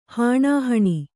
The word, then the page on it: ಹಾಣಾಹಣಿ: hāṇāhaṇi